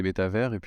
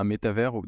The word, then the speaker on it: métavers: Pour moi, un métavers, déjà, comment on le prononce, métavers ou métaverse, en étymologie, donc c'est méta et ça le mot valise entre méta et univers, et en anglais, évidemment, universe, donc métaverse, donc bon, on peut dire métavers ou métaverse.